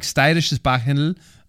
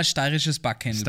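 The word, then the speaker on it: steirisches Backhendl: Sag mal dreimal steirisches Bachhändl. – Steirisches Bachhändl, steirisches Bachhändl, steirisches Bachhändl. What? I say it like a local, no? – Yeah.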